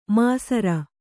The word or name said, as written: ಮೈಸಿರಿ